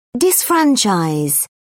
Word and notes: disfranchise: British English

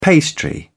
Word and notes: pastry: British English

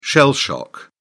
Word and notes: shell shock: British English

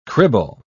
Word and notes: cribble: British English

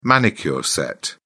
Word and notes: manicure set: British English

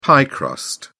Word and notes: pie crust: British English

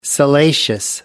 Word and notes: salacious: American English

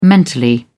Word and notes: mentally: British English

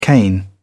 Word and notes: cane: British English

